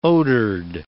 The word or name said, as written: odored